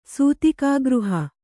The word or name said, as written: ಸೂತಿಕಾಗೃಹ